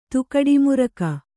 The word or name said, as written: ತುಕಡಿಮುರಕ